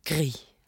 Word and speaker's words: gris: In Swedish, we say gris, which means pig. – But in French, yeah. You say gris. – Gris.